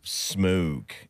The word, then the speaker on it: Smoque: Because it's spelled S-M-O-Q-U-E. – Oh, yes. Dude, really? Oh, wow. Mm-hmm. – Smoke.